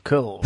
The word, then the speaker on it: curl: So wie Squirrel, nur ein bisschen verschluckt. Curl.